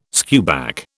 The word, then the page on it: skewback: British English